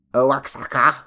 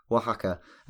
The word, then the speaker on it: Oaxaca: Uh, she said, "Apologies if someone has mentioned this and I missed it, but in several episodes you mention, and I'm going to say it how I used to say it, Oaxaca." Bless you. uh, yeah, and I think you should check your pronunciation. It's actually pronounced Oaxaca, and um, I think we talked about some snakes from this region in Mexico in uh, oh, episodes gone by.